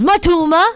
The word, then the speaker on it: Matoma: If you ask Matoma how to pronounce Matoma, he goes, Matoma!